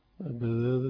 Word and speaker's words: البذاذة: والبذاذة بالباء الموحدة والدالين المعجمتين، البذاذة الدال المعجمة يعني الدال اللي عليها نقطة تبقى تنطق ذال يعني، وهي رثاثة الهيئة وترك فاخر اللباس.